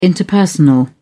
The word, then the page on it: interpersonal: British English